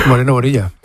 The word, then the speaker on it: Moreno Bonilla: No, como se dice así. Moreno Bonilla.